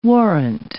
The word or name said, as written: warrant